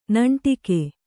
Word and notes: ನಂಟಿಕೆ: kannada

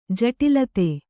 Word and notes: ಜಟಿಲತೆ: jaṭilate

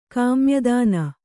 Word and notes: ಕಾಮ್ಯದಾನ: kannada

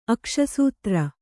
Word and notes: ಅಕ್ಷಸೂತ್ರ: Kannada

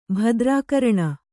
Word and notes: ಭದ್ರಾಕರಣ: bhadrākaraṇa

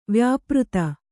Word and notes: ವ್ಯಾಪೃತ: vyāpřta

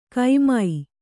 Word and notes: ಕೈಮೈ: kannada

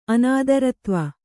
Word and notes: ಅನಾದರತ್ವ: Kannada

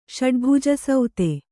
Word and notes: ಷಡ್ಭುಜಸೌತೆ: Kannada

